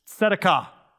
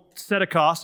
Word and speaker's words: tzedakah: So the Hebrew word for righteousness is tzedakah. The emphasis is on the first syllable, tzedakah.